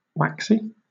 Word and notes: waxy: British English

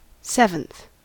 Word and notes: seventh: American English

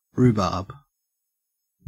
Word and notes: rhubarb: Australian English

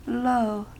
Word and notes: lo: American English

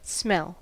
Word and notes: smell: American English

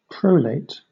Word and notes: prolate: British English

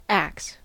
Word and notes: axe: American English

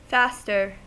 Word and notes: faster: American English

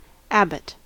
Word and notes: abbot: American English

